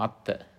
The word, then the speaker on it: atta: It can only massage your ego, your atta, A-T-T-A, atta, O, that's Pali, and O, A, long A, T-M-A, ma, atma, atman.